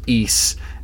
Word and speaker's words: Ys: It's just like yes, Y with an S. yeah, yeah, and it's pronounced East,